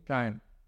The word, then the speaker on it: Shein: Like, it was AliExpress, now you're seeing more penetration from like Shein and all these other, is that how you say it, Shein? – Shine. – Okay. That's how you say it in Chinese.